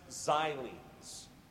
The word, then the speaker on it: xylenes: So it's pronounced xylenes. And it is with an S. It's a plural.